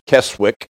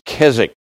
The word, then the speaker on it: Keswick: Looks like it should be pronounced Keswick, but the pronunciation is Keswick.